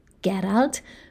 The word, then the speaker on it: Gerald: Gerald, pronounced with a G as in Gareth, not a J as in John, was his nephew.